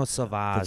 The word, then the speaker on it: Sauvage: No, Sauvage.